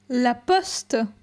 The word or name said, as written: La poste